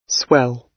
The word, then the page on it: swell: British English